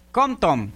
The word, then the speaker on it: Compton: Compton, si se dice así, ¿verdad? – Compton.